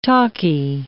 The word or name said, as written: talkie